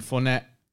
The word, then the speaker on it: Fournette: Leonard Fournette.